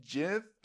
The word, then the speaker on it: GIF: Um, it's pronounced GIF, John.